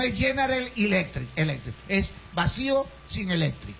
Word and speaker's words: General Electric: Entonces resulta que a mí me preocupa el hecho, porque, bueno, dijeron los del gobierno que iban a hacer un convenio con General Electric. ¿Está la pronunciación? ¿Qué le parece? – Muy bien. – Ve que molleja, ve, General Electric.